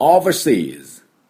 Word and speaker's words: overseas: Overseas で最後 Z Z を濁ります、ね、これがポイントです。じゃあ私の後について発音しましょう。 Overseas Overseas 完璧です。